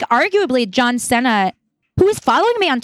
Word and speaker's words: Cena: Cena.